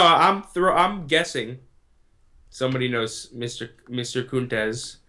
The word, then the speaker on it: Kuntz: Is that how you pronounce his last name? K-U-N-T-A-Z. – That's it. It's got to be it. No, it's Tom Kuntz. It's Kuntz and Mike McGuire.